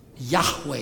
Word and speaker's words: Yahweh: Yahweh is the Hebrew, as best we understand how to pronounce it.